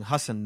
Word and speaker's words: Hasan: Also, Hasan Minhaj. I'm saying Hasan now. I've been saying Hasan. That's incorrect.